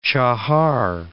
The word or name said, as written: Chahar